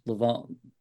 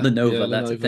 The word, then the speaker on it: Lenovo: They launched Leveno Ducati? Can't ever pronounce the computer name. – Yeah. – Lenovo.